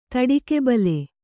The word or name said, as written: ತಡಿಕೆಬಲೆ